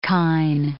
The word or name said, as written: kine